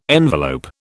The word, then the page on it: envelope: British English